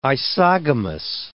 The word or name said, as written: isogamous